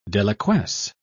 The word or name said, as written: deliquesce